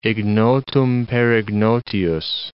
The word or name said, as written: ignotum per ignotius